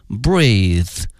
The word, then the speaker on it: breathe: 同时啊，呼吸这个词因为呢它的词性是名词，因此它的发音是 breath，而它的动词呢是 breathe， breathe。